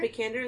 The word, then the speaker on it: Vikander: Vikander is how you say it.